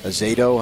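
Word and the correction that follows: Addazio: How do you say it? Adazio. – Adazio.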